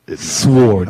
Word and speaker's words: sword: The W is silent, motherfucker. – Sword. – Yeah, it's sword.